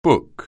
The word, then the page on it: book: anglais